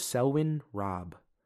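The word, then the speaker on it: Selwyn Raab: I'm like, it's really, I think you pronounce it Selwyn Robb. Selwyn is spelled Selwyn, and Robb is Raab.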